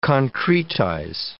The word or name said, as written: concretize